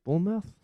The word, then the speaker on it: Bournemouth: Bournemouth? – Yeah, Bournemouth. Bournemouth? Yeah. – Yeah, Bournemouth. Bournemouth. There you go. They've defeated Bournemouth, and they're third in the championship at the moment, but they've defeated Bournemouth to go the fifth round, and they're taking on Everton.